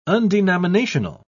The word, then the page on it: undenominational: British English